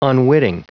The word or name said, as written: unwitting